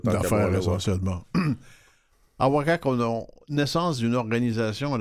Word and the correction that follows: Awashak: Awashak. Le C en Atikamekw se prononce comme un CH.